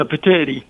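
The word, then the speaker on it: Petitti: How do you pronounce his name again? – Yeah, Patetti.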